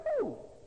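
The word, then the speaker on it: who (הוא): Who, because in the Semitic language, ho is not ho, is who. This word, who. Classically pronounced whoa, but pronounced in common language, who. Man who. You don't have to say man whoa.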